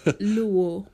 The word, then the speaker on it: Luo: Luya and Lua. – Luo. – Luo. Oh, okay. – L-U-O. – L-U-O. Luo. Okay.